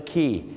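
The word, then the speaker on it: quay: ขาจะออกเสียงว่าคีย์ครับผมถูกต้องนะครับอย่างเช่นไปที่ออสเตรเลียที่ซิดนีย์ circular key นะฮะไปที่สิงคโปร์ครับ clark key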